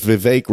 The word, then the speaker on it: Vivek: And then even if Vivek rhymes with cake, he'd have to be underground too.